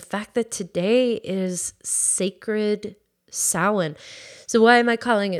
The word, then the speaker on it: Samhain: The origin of Halloween dates back over 2,000 years and evolved from the Celtic festival of Samhain, which kind of looks like Samhain, but it's pronounced Samhain.